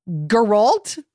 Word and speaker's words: Geralt: Yeah, I did a bunch of, like, quests for Geralt. Is that his name? – The hard G. – Geralt? – Yeah. Geralt.